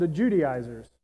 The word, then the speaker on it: Judaizers: But in his absence, we see this group that is starting to arise, pop up, and they're called the Judaizers. They're actually said it right for once. The Judaizers. Say that 10 times fast.